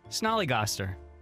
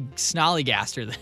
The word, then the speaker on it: snollygoster: Can you spell it? – Snollygoster? – Yeah. – S-N-O-L-L-Y. Snollygoster. – Gaster. – Gaster, G... – Snollygoster. – Or Goster, sorry. – Oh, well, I think you just gave me the clue. G-A-S-T... G-O-S-T. – G-O-S-T-E-R. – How the hell did you say Snollygaster then? – Because that's how they have it.